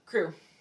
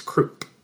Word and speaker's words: Croup: But in every adaptation, it's Croup. – Croup. – Yeah, Mr. Croup. Okay. C-R-O-U-P. And I always pronounce it like coup, you know? – Me too. – But no, it's Croup.